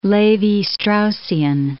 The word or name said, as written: Levi-Straussian